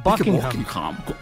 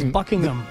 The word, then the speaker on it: Buckingham: Es Buckingham. – Eso sí. Y de hecho, mi inglés perfecto, el H medio que se es medio silente. Buckingham. Buckingham.